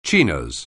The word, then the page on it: chinos: anglais